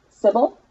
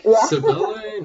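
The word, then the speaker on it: Cibele: How do you pronounce it again? – Civil. – Civil. Okay. I pronounced it a dozen different ways. Like, Civile? No.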